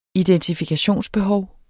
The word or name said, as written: identifikationsbehov